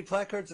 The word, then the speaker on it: placards: Why do we call them placards? – Because I don't know how to pronounce it. And you've been letting me. – No, that's right. Placards. – All right. I don't know. Placard? Captain Picard? – Now, you just flew on a plane a bunch of times recently. Do you remember them saying, pay attention to lighted signs and placards? You're saying it right.